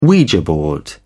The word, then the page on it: ouija board: British English